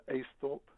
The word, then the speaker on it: Aisthorpe: Uh, Aisthorpe.